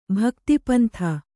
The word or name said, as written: ಭಕ್ತಿಪಂಥ